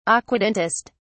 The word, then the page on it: aquatintist: British English